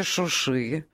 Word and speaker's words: Шуши: В городе Шуши Я произношу так, как это принято в армянской традиции, потому что это... Я понял, да? – Я объясню, почему. Нет. Потому что это северные сузы. Это название шумерское, вообще-то говоря.